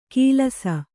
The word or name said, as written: ಕಿಲಾಸ